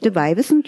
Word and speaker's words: De Weiwissen: De Weiwissen. – Ist das so richtig ausgesprochen? De Weiwissen, die alten Weiber, ja.